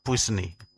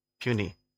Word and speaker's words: puisne: It's spelled P-U-I-S-N-E, but it is not pronounced Pusni. It's pronounced puny.